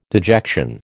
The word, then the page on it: dejection: American English